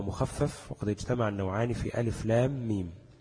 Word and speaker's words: الم: ألف لام ميم، ألف لا تمد، اللام تمد 6 حركات، وآخر كلمة لام حرف الميم، ثم أول ميم حرف الميم أيضا، يعني لام تنطق لام ألف ميم، والميم تنطق ميم ياء ميم. فهيلتقي حرف الميم اللي هو في آخر كلمة لام مع حرف الميم في أول كلمة ميم، تمام؟ فهيبقى مدغم، يعني هتبقى لام ميم، تمام؟